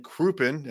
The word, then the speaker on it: Crouppen: Yeah, as a managing partner with Brown and Crouppen, am I saying that correctly? – You are.